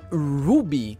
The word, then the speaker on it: RWBY: E a gente teve Ruby, que se escreve com RWBY, Ice Quindon.